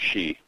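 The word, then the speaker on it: sidhe: Hi, I was just listening to your podcast, and I understand you mispronounced Banshee. S-I-D-H-I-E is pronounced she.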